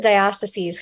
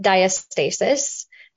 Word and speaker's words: diastasis: Well, I pronounce it diastasis because I'm from the West, but in some parts of the world, like, it's pronounced diastasis. Just kind of depends on where you're from.